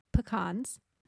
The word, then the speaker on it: pecan: How do you say P-E-C-A-N? – Pecans. – Oh, we got one different finally. – What do you say? – Pecan. – Oh, I don't like saying pecan.